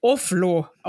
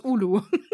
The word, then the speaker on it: Oulu: wo die Stadt Oulu einfach mit Oflo ausgesprochen das war, wurde. Stimmt, das war Oflo und bei ja. den Radioreisen war es Ulu. ja, aber noch, noch lieber Ulu als Oflo.